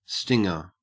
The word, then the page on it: stinger: Australian English